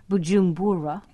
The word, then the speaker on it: Bujumbura: But he's beginning to hear a sort of, this is in the capital of Burundi, am I pronouncing it right, Bujumbura, yeah.